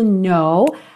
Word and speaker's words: know: Repara que o K da palavra know é mudo.